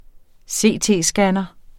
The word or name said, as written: CT-scanner